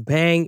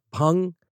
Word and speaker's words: bhang: It's spelled B-H-A-N-G. In the U.S., a lot of people pronounce it as bang. It's actually pronounced pung.